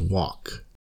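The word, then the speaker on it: 2VALK: You can also find the track on his album, Walk, spelled 2V-ALK, but pronounced Walk.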